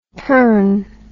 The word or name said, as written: pern